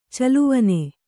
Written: ಚೆಲುವಾನ್

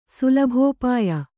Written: ಸುಲಭೋಪಾಯ

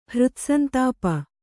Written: ಹೃತ್ಸಂತಾಪ

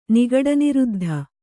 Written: ನಿಗಡನಿರುದ್ಧ

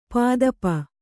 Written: ಪದುಪು

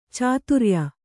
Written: ಚಾತುರಿಯ

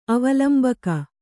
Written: ಅವಲಂಬಕ

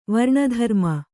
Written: ವರ್ಣಧರ್ಮ

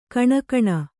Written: ಘಿಣಿಘಿಣಿ

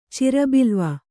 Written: ಚಿರಬಿಲ್ವ

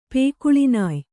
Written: ಪೇಕುಳಿನಾಯ್

